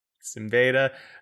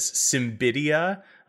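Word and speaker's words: Siembieda: Simbada. I got it totally wrong when I was a kid and read it as Simbidia.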